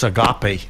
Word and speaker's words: Agape: A-G-A-P-E. – And it's Agape. – Agape, yes.